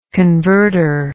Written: converter

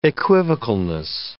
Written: equivocalness

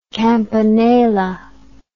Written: campanella

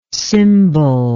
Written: cymballike

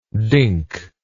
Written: dinkly

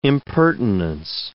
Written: impertinence

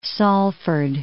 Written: Salford

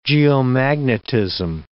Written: geomagnetism

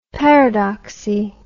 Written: paradoxy

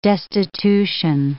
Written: destitution

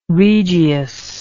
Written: regius

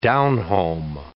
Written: down-home

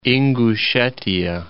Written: Ingushetiya

